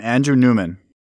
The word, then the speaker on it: Andrew Newman: I'm Andrew Newman. – Can you spell it, please? – A-N-D-R-E-W-N-E-W-M-A-N.